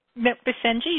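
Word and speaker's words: Basenji: She currently lives in Whittier, California with Mr. Rinaldi, Bazenji, and Ragdoll Fur Babies. Did I say that incorrectly? – Bazenji, you got it right. First try out.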